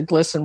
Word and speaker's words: GLSEN: There's something called GLSEN, G-L-S-E-N. It's not GLSEN, it's G-L-S-E-N.